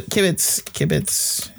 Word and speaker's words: kibitz: Kibitz means what? Kibitz. Yeah. – Said it right.